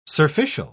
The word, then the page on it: surficial: British English